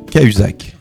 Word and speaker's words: Cahuzac: Casuac, si tu nous avais bien écouté, si tu peux encore le faire. – Reprononce-le pour voir. – J. Cahuzac. – Cahuzac. Oui. Tu avais dit Casuac.